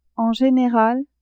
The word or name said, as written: en general